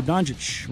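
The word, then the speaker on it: Dončić: Dallas they uh, beat the Pelicans, but once again, Luka Doncic rhymes with Jokic.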